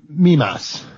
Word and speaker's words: Mimas: Mimas.